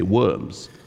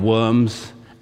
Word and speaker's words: Worms: He would later go on to this place, Worms, or as we say in English, because we don't pronounce the V, we say W, we say Worms.